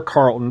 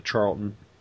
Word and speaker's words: Charlton: And we're going with Charlton Comics this time around, or Carlton, depending on your pronunciation. I've always called it Charlton.